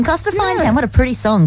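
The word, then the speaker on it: Corstorphine: I'll spell it C-O-R-S-T-I-P-H-I-N-E, Costa Fine.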